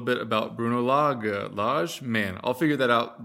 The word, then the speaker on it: Lage: It's like a Lage with a soft G.